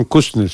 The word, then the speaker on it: Kuznets: El señor bielorruso Kuznets, ¿se pronuncia bien así? – Simon Kuznets, sí.